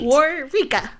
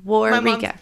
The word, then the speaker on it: Waurika: Waurika. – Waurika.